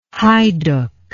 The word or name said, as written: haiduk